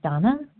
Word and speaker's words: Dana: This is Donna B., recovered compulsive overeater and bulimic in Denver, Colorado. My name is pronounced Donna, but it is spelled D-A-N-A.